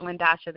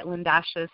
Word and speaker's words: Lyndosha: So you can connect with me on Instagram at Matchless Living, one word, and also at Lindasha. That is my personal page. It's spelled LYNDOSHA, D as in dog. You can also connect with me on YouTube at Lindasha space E as an egg.